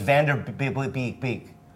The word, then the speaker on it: Vanderbeek: And I have a new Viceland show coming out called What Would Diplo Do with James Vanderbeek.